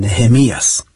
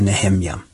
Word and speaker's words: Nehemja: Det skyldes kanskje delvis at før så uttalte vi kanskje ordet annerledes, Nehemias. Men slik som det er skrevet i norsk form nå, så kan vi ikke uttale på en måte Nehemja. En venn av meg holdt en gang en forelesningsrekke over profeten Habakkuk, eller kanskje jeg skulle kalle det en bibeltimeserie.